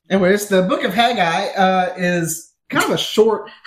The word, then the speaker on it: Haggai: How do you say that? We need to clear the air on the pronunciation. – Haggai? – I was wondering that. – Haggai? I thought it was Haggai. – Is it just Haggai? – It's Haggai.